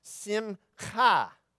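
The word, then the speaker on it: simcha (שמחה): And this is not cha, it's more like Bach. You know the composer Bach? Bach. That's the cha. All right? So it's pronounced simcha.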